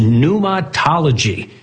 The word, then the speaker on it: pneumatology: It is spelled with a silent P. It is pneumatology. And the reason it's called that is because the Greek word for spirit is pneuma, thus pneumatology.